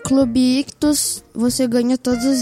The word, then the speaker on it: Ichthus: Lembrando que Ictus se escreve I-C-H-T-H-U-S. Então tem dois Hs, tá bom?